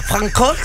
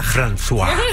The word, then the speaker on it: François: Francois. Francois Letexier. – François.